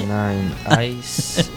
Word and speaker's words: 69 Eyes: Se llaman los 69 Eyes. No sé si lo he dicho bien del todo. – 69 Eyes. Sí, bastante bien.